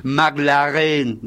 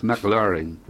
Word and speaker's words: McLaren: O vos decís McLaren. No, es McLaren.